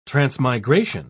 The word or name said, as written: transmigration